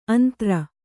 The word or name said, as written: ಆಂಧ್ರ